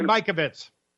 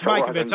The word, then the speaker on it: Mikovits: But he had a guest on the other day, Dr. Judy Majovitz. And no, Judy Mikovits. – Okay, well, I didn't know if I was pronouncing it right or not. – Yeah, it's Mikovits.